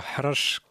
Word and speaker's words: Harash: Alors, Harash, que tu prononces super bien,